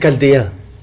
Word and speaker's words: Chaldéens: Le premier, c'est la magie de ce qu'on a dit en arabe, al-Kuldaniyoun, en Al-Kushdaniyoun, en français les Chaldéens, d'accord? Ça se dit K, mais ça s'écrit CH, d'accord?